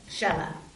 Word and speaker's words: Shelah: Shelah.